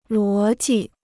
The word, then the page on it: 逻辑: luó ji